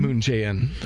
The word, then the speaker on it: Moon Jae-in: Moon Jae-in. – Moon Jae-in.